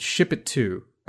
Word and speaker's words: Shipito: So what I did instead is there is a service called Ship It To. That's how they want you to say it, but it's spelled Ship ITO, so Shipito, .com.